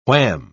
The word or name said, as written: wham